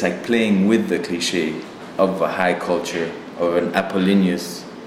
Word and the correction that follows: Apollonian: I don't know how you say it really in English. – Apollonian. – Apollonian.